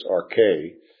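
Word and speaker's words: arche (ἀρχή): Arche, or actually the emphasis is on the last syllable, it's arche.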